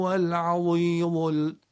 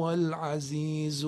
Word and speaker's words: عزیز: یعنی اگر بگیم و هو العظیم، اینا دیگه غلطه. و هو العزیز الحکیم، همونطوری که زی فارسی رو تلفظ میکنیم.